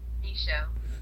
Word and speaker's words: Michaud: Michaud.